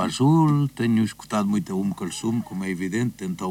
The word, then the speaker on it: Umm Kulthum: Esse músico que eu não vou usar a pronunciar, que tu disseste anteriormente, é Umm Kulthum, é uma cantora egípcia. – Ah, Umm Kulthum, sim.